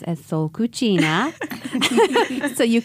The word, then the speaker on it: cucina: So cucina is the Italian pronunciation. In Spanish, you will say cocina, and it means kitchen in both languages.